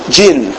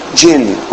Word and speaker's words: Djin: No, D-J-I-N. How do you say that? D-J-N? Jin. Jin.